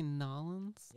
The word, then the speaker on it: New Orleans: Did you just say Nolens? – Yeah, that's Ooh. How they say it down there.